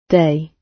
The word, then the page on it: day: British English